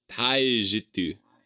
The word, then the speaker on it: Taijitu: It's called the Taijitu. That's that was an excellent pronunciation.